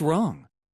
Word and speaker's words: wrong: What's wrong? – 자, 이것도 많이 들었던 표현이죠? What's wrong? 했고요. 우리가 쓸 때는 이렇게 써야죠. 앞에 W는 묵음입니다.